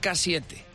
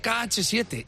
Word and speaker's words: KH7: Y ya le digo, mamá, que no se dice así, que se dice KH7. Y me dice, se dice K7 porque la H es muda.